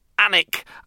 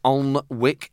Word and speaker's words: Alnwick: Annick, and people keep telling me that's how to pronounce it. It's not Alnwick, it's panic.